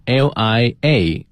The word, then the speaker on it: liaison: 就是与谁谁联系，比如说好多的外企它在国内设立代表处啊，它就是为了联系亚洲地区的各个分支机构，那么这个时候呢你可以说这个代表处是一个 liaison office你也可以说 this office liaise with other offices across Asia，这个 liaise 和 liaison 就是这样的用法，这个 liaison 呢是 liaison，